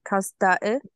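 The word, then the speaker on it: Kazdağı: And in Turkey it's called Kasda, which means I think I pronounced that wrong. Kasdae. – You're right, yep.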